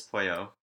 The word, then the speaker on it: Pollo: It's Pollo. – It's spelled, Pollo. It's pronounced Pollo, like a Pollo.